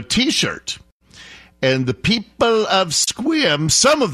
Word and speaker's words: Sequim: You know what's happening in Squim? It's spelled Sequim, but it's pronounced Squim.